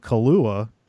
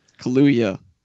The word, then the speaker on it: Kaluuya: I have learned how to pronounce Daniel Kaluuya for Get Out. I know that's how you say it. – Kaluuya. – Kaluuya.